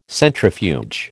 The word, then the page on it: centrifuge: American English